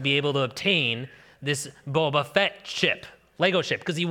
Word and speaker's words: Boba Fett: Boba Fett, yeah. Pronounce it right. Bubba. Not Bubba Fett.